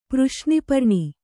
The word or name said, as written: ಪೃಶ್ನಿಪರ್ಣಿ